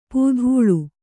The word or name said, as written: ಪೂಧೂಳು